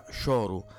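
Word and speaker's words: choro: Fuori dal cioro, questo è il gioco di parole eh, del trio di Manuel Magrini, il cioro che si pronuncia in realtà scioru è un termine portoghese ed è, mm, eh, va a rappresentare la struttura musicale portante del folk brasiliano.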